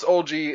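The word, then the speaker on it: Oldziey: His last name is pronounced Olgi. It's spelled Oldzieey.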